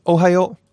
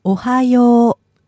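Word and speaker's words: Ohio (おはよう): But if you listen to the pronunciation, it's quite different. This is Ohio, but you'll notice when says good morning in Japanese, you kind of hold the last part.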